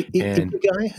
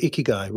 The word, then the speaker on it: ikigai: Oh, no, sorry, ikigai, is it? Yeah, I think ikigai, reason to live. – It's ikigai.